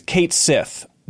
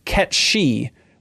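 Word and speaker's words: Cait Sith: Oh boy, this is where it gets confusing because the cat's name as we know it is Kate Sith. That's what we all called it growing up. Apparently it's Scottish and it's actually pronounced Ketshi, which I don't even know if in Dirge of Cerberus they say it.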